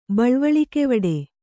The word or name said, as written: ಬಳ್ವಳಿಕೆವಡೆ